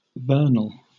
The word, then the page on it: vernal: British English